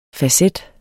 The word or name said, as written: facet